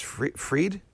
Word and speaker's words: Fried: which with Jason Fried. Jason, I've interviewed him many times. Fried? – Fried, yep.